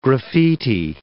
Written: graffiti

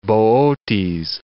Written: Bootes

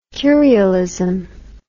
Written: curialist

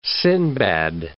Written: Sinbad

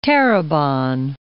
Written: Terrebonne

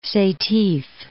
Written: Setif

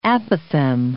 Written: apothegm

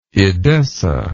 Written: Edessene

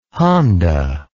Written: Honda

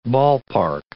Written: ballpark

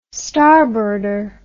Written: starboarder

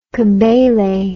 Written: kebele

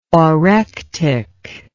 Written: orectic

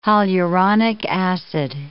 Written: hyaluronic acid

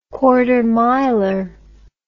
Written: quartermiler

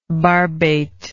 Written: barbate

